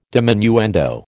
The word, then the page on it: diminuendo: American English